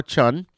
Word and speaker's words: Dajun: He played, Su Yu played Chan Tao Chun, and it's spelled Dajun, D-A-J-U-N, but it's pronounced Chun.